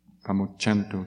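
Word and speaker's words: pamuccantu: The C is always pronounced cha. – Então, o C, por exemplo, sempre pronunciado cha. Deixa eu ver se tem algum onde é que está. Examples, like word. – Pum pum pum buchantu under karuna. Sambe sata sambaduka pamuchantu under compassion. – Ah, tá. Então, ali embaixo, na compaixão, tem um exemplo do Pamuchanto, que é como os dois Cs ali, é meio que pronunciado cha.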